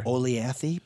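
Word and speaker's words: Olathe: Oleathy, perhaps. – Oh. – Now you're showing off. – Look at that. And you even pronounce it like the local.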